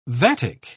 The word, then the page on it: vatic: British English